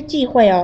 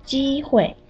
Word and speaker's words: 机会: 如果我有机会，志敏不是忌讳哦，应该是机会。